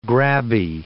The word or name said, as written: grabby